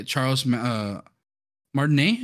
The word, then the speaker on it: Charles Martinet: And she says that it should have been voiced by Charles Martinet, is how you say it, right? – Yeah.